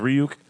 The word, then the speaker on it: Ryuk: He plays a demon that is pronounced Ryuk, which is the name of the big demon that drops the book in the first place.